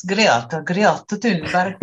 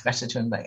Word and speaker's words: Greta Thunberg: It's Greta Thunberg. But nobody's going to say that, so it's Greta Thunberg.